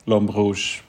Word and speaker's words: L'Homme Rouge: And so Le Homme Rouge, I don't know if I'm pronouncing that correctly. – It's a French name, so it's like L'Homme Rouge. But yeah, in Swedish, it's like L'Homme Rouge too.